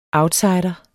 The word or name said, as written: outsider